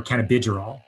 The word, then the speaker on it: cannabigerol: We also have a very novel cannabinoid in this formula called CBG, or cannabigerol is how you say it.